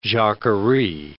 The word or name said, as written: jacquerie